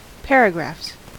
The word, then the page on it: paragraphs: American English